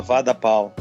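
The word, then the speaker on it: Vada Pav: Se chama Vada Pau. E escreve Vada Pave, só que pronuncia como Vada Pau.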